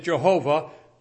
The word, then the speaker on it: Jehovah: By the way, you'll see in the notes later on, I use the spelling for, well, Tyndale says, let's pronounce it Jehovah. Later scholars have said it may have been more likely pronounced Yahweh or something like that.